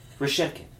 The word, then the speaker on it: Reshetikhin: Reshetikhin.